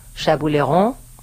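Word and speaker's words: Chambouleyron: Él se llama Brian Chabouleron. Se lo pronuncia en francés para que diga, mira cómo habla.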